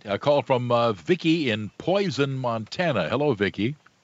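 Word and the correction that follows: Polson: Hello, in Poulsen. – Oh, Poulsen. I did mispronounce that. It is Poulsen, Montana.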